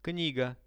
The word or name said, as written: kniga